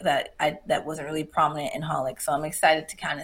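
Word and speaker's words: xxxHOLiC: It was like XXX Holic, but it was like, but it's pronounced Holic.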